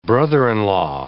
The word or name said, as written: brother-in-law